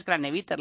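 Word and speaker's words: Kranevitter: Al fin y al cabo, al ser argentino, pues se lee cómo se pronuncia, o por lo menos así lo hacemos, aunque sea de origen austriaco, alemán, lo que sea. Para nosotros es Craneviter.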